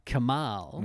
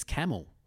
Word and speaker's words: Kamal: Although, like Diana Arnaid, he also added an H to it because it's spelled K-A-M-A-L. – Right. – And he said people would introduce me as Camel. – Right. – He's Camel, so he put the H there.